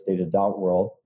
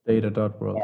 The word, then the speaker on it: data.world: That's actually how you pronounce it, by the way. It's Data.World. – Data.World, and got it.